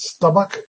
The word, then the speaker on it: stomach: Stomach is pronounced stomach. And one way of remembering that is a stomach pump.